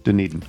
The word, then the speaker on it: Dunedin: From Jason Welsh in Dunedin, Florida. I hope I pronounced that correctly. That's where I think the Blue Jays always trained, Dunedin.